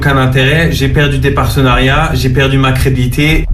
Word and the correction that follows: crédibilité: Non, alors tu as oublié une syllabe, tu as oublié le lit. – Crédibilité. – Débilité, c'est comme débilité.